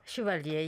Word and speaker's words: Chevalier: Is that the right pronunciation? My French is terrible. – Chevalier, yes.